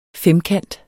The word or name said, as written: femkant